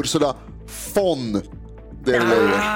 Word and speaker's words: Ursula von der Leyen: Det är Ursula von der Leyen. – Men jag uttalade ju von som fonetiskt alltså. – Precis, fel.